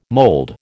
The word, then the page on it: mold: American English